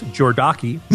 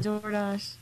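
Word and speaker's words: Jordache: Jeans that they keep pronouncing as Jordaki. – Oh my God, Jordash.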